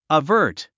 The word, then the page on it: avert: əvə́ːrt; アバート